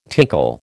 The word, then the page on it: tinkle: American English